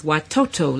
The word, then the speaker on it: watoto: The Swahili word for children is watoto.